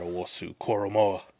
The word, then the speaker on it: Owusu-Koramoah: Watching Jeremiah Owusu-Koramoah, actually said his name right this time, nice.